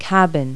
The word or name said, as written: cabin